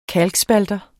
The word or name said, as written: kalkspalter